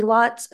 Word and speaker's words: Lotz: My name is Izzy Lotz, Associate Planner.